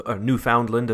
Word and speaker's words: Newfoundland: Been to Newfoundland, Newfoundland, as it's pronounced over here.